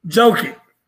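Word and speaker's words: Jokić: But talk to him real quick, about your boy, what's his name, Jokovic or whatever. How you say his name? – Jokic? – Jokic. – Yeah.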